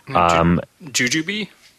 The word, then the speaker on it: jujube: That's spelled J-U-J-U-B-E. – Jujube? – Jujube, yeah. Yeah, exactly.